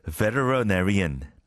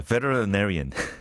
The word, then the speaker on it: veterinarian: Kind of a literary보다 한 몇 배 어려운 건데 veterinarian 수의사 veterinarian 정말 힘들죠. And again, if you pronounce it the British way, it's much easier. But it's those American, pronunciation that treats T as L, veterinarian.